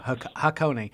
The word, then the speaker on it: Hakone: And of course, I was butchering it, pronouncing it how I thought it was pronounced. So Hakone.